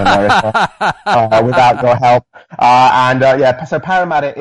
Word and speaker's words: Parramatta: Tell us something about St. John's Anglican Cathedral in Parramatta, and am I pronouncing Parramatta correctly? – Yes, Parramatta. So yeah, we're out here in Sydney, Australia.